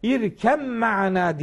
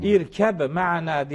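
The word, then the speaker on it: irkem ma'ana (ارْكَبْ مَعَنَا): Hani irkem ma'ana diye okunur bu idgamla. Evet. Bu idgamın da çok önemli bir mantığı vardır. Sen de bizimle karış yani. Hmm. İrkeb ma'ana diye böyle ayrı okunmaz. Bitişik okunur.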